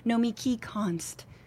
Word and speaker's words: Nomiki Konst: Please welcome Nomiki Konst. You said it right.